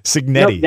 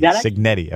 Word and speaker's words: Cignetti: Cignetti. Cignetti.